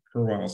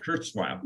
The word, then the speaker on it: Kurzweil: Little fun fact, I have in my garage one of the very first Kurwile songs. I can never say his name. Help me out. Ray's last name. – Kurzweil.